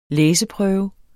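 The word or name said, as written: læseprøve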